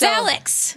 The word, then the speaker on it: Alex: No, it's the same thing with they call him Alec. – I know, it's Alex.